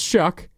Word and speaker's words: Shough: Spelled S-H-O-U-G-H. Pronounced Shuck.